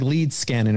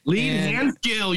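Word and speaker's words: Leedskalnin: So he said, pronounced it the right way. – No, I'm sure you didn't because I've been there. It's like lead scanning or something. – Lead hand skill, you son of a bitch.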